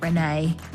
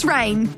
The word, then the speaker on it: Reyne: I haven't heard of Rain before, but it's spelled R-E-Y-E. Oh, no, I've spelled that wrong. R-E-Y-N-E. Was it? Is that Rain? Yeah, that's Rain. Surely that's Rain. R-E-Y-N-E. It's not Renee. Is it Renee? Rain? Rain. I think it's Rain.